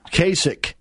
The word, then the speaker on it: Kasich: Kasich.